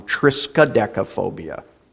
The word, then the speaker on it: triskaidekaphobia: The fear of the number 13 is called tristodecaphobia. Or I think I said that wrong, triskaidekaphobia.